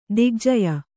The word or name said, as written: ದಿಗ್ಜಯ